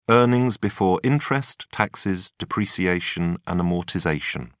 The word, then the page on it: earnings before interest taxes depreciation and amortization: British English